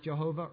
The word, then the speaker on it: Jehovah: It can be pronounced Jehovah or Yahweh.